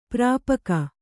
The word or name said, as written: ಪರಿಪಾಕ